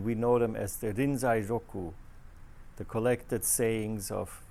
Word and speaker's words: Rinzai: That happens to be the Japanese pronunciation of his name, Rinzai Gigen, who lived sometime in the 8th or 9th century in China.